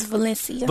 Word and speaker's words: Valencia: It's Valencia.